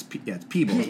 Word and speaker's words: Peebles: Mario Van Pebbles. – Yeah, it's Peebles. But that's why you think Pebbles, but there's two E's. There's an E in place of a B.